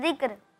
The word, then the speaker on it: ذکر: اچھا اس کا صحیح تلفظ, اس کا صحیح تلفظ ہے ذکر.